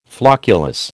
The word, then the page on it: flocculus: American English